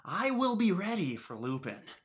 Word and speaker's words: Lupin: It's Lupin, sir. – What? – His name. It's pronounced Lupin. – But why? It's spelled L-U-P-I-N. – Just trust me on this one, sir. Everyone says Lupin for some reason.